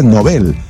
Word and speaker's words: Nobel: Premio Nobel se pronuncia como palabra aguda, con acento en la última sílaba, explica Diario de Cultura, porque así se pronuncia el apellido del creador. – Mira. – Alfred Nobel, en su idioma, el original, el sueco.